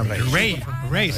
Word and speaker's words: Raye: Ray.